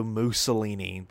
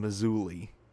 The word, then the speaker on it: Mussolini: Benito Mussolini. The guy that you learn about in school. And he called him Mazzulli.